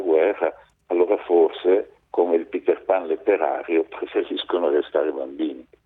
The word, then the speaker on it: Peter Pan: No, della letteratura per l'infanzia, che quello si pronuncia in inglese perché il suo autore è inglese, quindi si pronuncia Peter Pan, diciamo così.